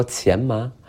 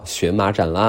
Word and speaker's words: 荨麻疹: 我说前麻。他说，哼哼，一猜你就查过字典。可是我们现在最新的读音呀，它又叫荨麻疹了，嘿嘿，想不到吧？